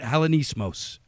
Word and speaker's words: Hellenismos: Is that how you say it? Hellenismos. – Hellenismos, right.